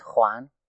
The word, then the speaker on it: Juan: Hello, my name is Juan.